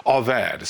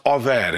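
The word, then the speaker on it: avad (עבד): Because in the Hebrew, this word is, the root word of this is the word avad. It's spelled A-B-A-D, but it's pronounced avad.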